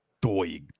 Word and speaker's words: Doig: Doig. – Did I say his name right? – No, you said it right.